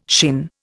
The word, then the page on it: shin: Indian